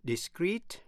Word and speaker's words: discreet: Discrete, D-I-S-C-R-E-E-T là giữ kín. Và discrete, D-I-S-C-R-E-T-E là rời rạc. Phân tách. Phát âm như nhau, discrete.